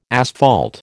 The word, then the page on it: asphalt: American English